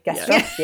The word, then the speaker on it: gastrostomy: Gastronomy? Am I saying it right? Gastrostomy? – Gastrostomy.